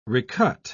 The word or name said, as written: recut